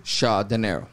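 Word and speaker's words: Shah Dinero: Shaw De Niro. – Whew, I love that. How do you spell that, dog? – S-H-A-H, bruh.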